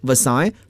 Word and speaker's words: Versailles: 我们再看这句话中的专有名词 Versailles，这是一个典型的法语词，我们要注意法语的发音 Versailles，凡尔赛宫。